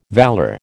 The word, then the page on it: valour: American English